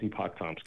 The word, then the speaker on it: Epoch Times: Epoch Times, or however you say it. – Yeah, I think it's Epoch Times.